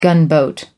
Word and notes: gunboat: American English

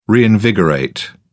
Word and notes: reinvigorate: British English

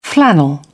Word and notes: flannel: British English

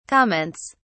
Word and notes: comments: British English

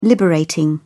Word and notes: liberating: British English

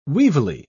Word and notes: weevilly: British English

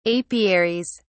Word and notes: apiaries: British English

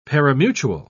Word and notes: parimutuel: British English

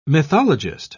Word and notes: mythologist: British English

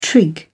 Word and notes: trig: British English

